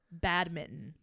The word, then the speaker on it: badminton: So apparently from our paused research, there is an American pronunciation and a British pronunciation. You are saying it the British way of badminton. And the American pronunciation is badminton.